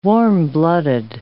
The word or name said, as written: warm-blooded